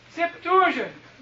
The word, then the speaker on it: Septuagint: I'd say Septuagint.